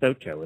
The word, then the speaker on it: Ochoa: But there's a quarter horse a three-year-old by the name of Ocho, O-C-H-O-A, which I think is pronounced Ocho, isn't it? – Ocho, yeah, that's eight.